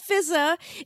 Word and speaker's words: Fizaa: It's not spelled F-Z-A, it's spelled F-I-Z-A-A with two A's at the end, but it's not pronounced Fizza, it's just pronounced Fizza. One of the A's is silent, I don't know which one. Maybe they take turns, you know, like twin babies playing the same role in a beloved American sitcom.